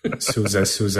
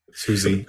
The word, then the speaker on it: SUSE: SUSE. Yeah. SUSE. – I read years ago that it is to be pronounced the same way as the American band writer, John Philip Sousa.